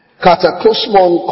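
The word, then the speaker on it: kataklusmon (κατακλυσμόν): K-A-T-A-K-L-U-S-M-O-N. K-A-T-A-K-L-U-S-M-O-N, kataklusmon, then kosmon, that's kosmo, you know that already, K-O-S-M-O, asebon, epaxas, E-S-E-B-O-N-E-P-A-X-A-S, by the way, I always use strongs, kataklusmon, kosmon, asebon, epaxas.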